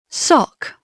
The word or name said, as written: sock